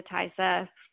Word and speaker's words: Taissa: So actresses-wise, there's Sister Irene, who's I'm butcher this, T-A-I-S-S-A, so that's Tessa Formiga, and Bonnie Ahrens is a demon nun.